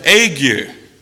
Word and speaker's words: ague: Yeah, this is said ague.